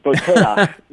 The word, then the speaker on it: Boteach: Now, Rabbi Shmuley Boteach, am I pronouncing that somewhat correctly? – That's a good Gentile pronunciation, Boteach. Shmuley Boteach, yep.